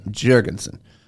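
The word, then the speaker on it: Jorgenson: That's Am I pronouncing this correctly? – That's very aggressive. It's either that or Eric Jorgensen. – Jorgensen.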